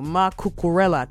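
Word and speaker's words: Marc Cucurella: To football, Mark Kukure. Hey, God, I don't want anybody to tell me later on that I mispronounced it. Domina Noveso. Mark Cucurella, 28 years old.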